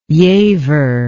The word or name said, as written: Giaever